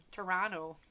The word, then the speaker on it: Toronto: Toronto. – Don't pronounce that second too.